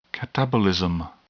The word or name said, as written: catabolism